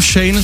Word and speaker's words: Shein: Na skvál jsem ji vynechal, protože si nejsem jistý, jestli se vyslovuje jako Shein, Shein nebo Shein. – Nevím, my to tady... – Známe všechny varianty. – Já nevím, já to říkám Shane, což já si mi říkám, že je špatně.